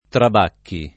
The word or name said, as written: Trabacchi